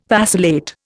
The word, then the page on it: vacillate: Indian